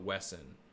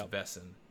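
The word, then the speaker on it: Wesen: It shows like the Grimm's, one of the Grimm's best friends is a Vessen, which is spelt Wesson, but it's Vessen.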